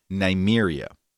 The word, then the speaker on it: Nymeria: So her name is Nymeria. Rhymes with what? Siberia.